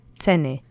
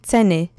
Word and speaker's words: ceny: Let's say ceny together three times. Accent is on the first syllable. Ceny. Ceny. Ceny. Perfect. Remember that C's in Polish get pronounced ts, ts, okay?